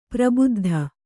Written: ಪರಿಬದ್ಧ

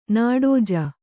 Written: ನೀಡಜ

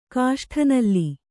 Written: ಕಾಷ್ಠನಲ್ಲಿ

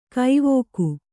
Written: ಕವಿಕೆ

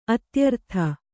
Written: ಅತ್ಯರ್ಥ

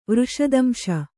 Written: ವೃಷದಂಶ

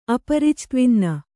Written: ಅಪರಿಚ್ತ್ವಿನ್ನ

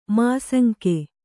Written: ಮಾಸಂಕೆ

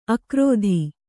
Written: ಆಕೃತಿ

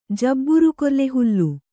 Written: ಜಬ್ಬುರುಕೊರ್ಲೆಹುಲ್ಲು

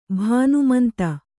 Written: ಭಾನುಮಂತ

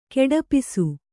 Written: ಕೊಡಪಿಸು

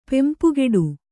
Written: ಪೆಂಪುಗೆಡು

